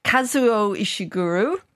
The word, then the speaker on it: Kazuo Ishiguro: So I had to Google how to pronounce his name properly over the weekend. – Okay. – So it's Kazuo Ishiguro.